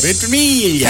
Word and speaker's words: Ventimiglia: See if you can pronounce it. V-E-N-T. That's Vent, right? – Yeah. – I-M Ventim I-G-L-I-A. Iglia. – Ventimiglia. – Milo Ventimiglia. – Ventimiglia. – Hey, Ventimiglia. That sounds pretty good.